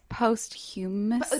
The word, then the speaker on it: posthumously: How do you say that? I do not know. – I think it's posthumously. – That's right. Posthumously.